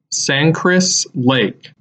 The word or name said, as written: Sangchris Lake